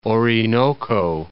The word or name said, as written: Orinoco